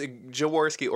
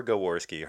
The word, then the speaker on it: Jaworski: It's Jaworski or Gaworski, hard or soft G either.